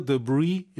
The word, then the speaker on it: debris: And check the outdoor unit for leaves or other debris. 여러분, 이 단어 발음 꼭 기억하세요. Debris, S가 발음이 안 돼요.